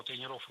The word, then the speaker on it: тренеров: Тренеров, неверно. Перед нами, опять же, слово с неподвижным ударением. И двигать это ударение мы не должны. Всегда ударяем «е» в первом слоге. Тренеры, тренерам, тренеров.